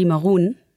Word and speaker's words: Maroun: It's Eddy Maroun.